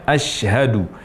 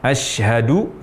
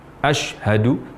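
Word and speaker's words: ashadu (أشهد): Tidak seharusnya terucap demikian, seharusnya ashadu. Ashadu huruf shin. Kesalahan ketiga, mengucapkan huruf shin dengan membulatkan bibir atau memonyongkan bibir. Misalnya ashadu.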